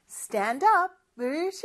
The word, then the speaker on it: stand up: 그리고 발음할 때는 연음이 있어요. 자, 연음이라는 건 뭐냐? 이어져서 나는 소리거든요. 영어는 이렇게 stand up 두 개의 단어가 있지만 마치 하나의 단어처럼 이어져서 발음이 되는 거죠. 그래서 stand up 이렇게 D 발음이 이어집니다. stand up, stand up이 아니고 stand up 이렇게 발음을 하는 거죠.